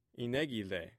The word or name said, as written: inegi